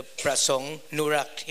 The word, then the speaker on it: Pak-Prasong Norack: The Pak-Prasong Norack is my real name.